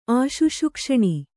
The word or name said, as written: ಆಶುಶುಕ್ಷಣಿ